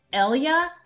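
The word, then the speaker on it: Eyla: Well, we have Danny Sterling with us today from Elia. Am I pronouncing that correctly? Elia Food. – You nailed it. – Yay. Hi, Danny. – Hey. – That was my first question. How do you pronounce this thing? It's like capital E, capital Y, capital L, capital A. I mean, yeah. We wanted it to sound like hell yeah, because we want people to get excited about a vending machine.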